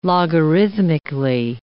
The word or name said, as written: logarithmically